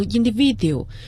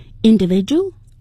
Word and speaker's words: individual: individual 要注意，很多人有可能把这个单词读成 individual，因为见到了 d 和后面 u 在一起，但实际上在英文中地道的发音是 j 这样的声音，因为发生了声音连读的情况，所以个性英文叫 individuality。 – individual， individuality。